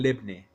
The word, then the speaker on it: lebne: Aquí en México la llaman lebne, porque es como se pronuncia en sirio y en libanés, que son las comunidades más importantes.